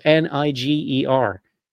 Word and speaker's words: Niger: Now, this is a country called Niger, and it's spelled N I G E R, and it's pronounced Niger. And uh, for years in Ireland, we were all mispronouncing it.